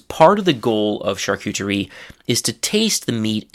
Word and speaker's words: charcuterie: So, the French pronunci- pronunciation is charcuterie, something like that. Hopefully, my French pronunciation has not decayed too much in the past several years since I've spoken it regularly, but the English pronunciation is actually, again, pretty close charcuterie.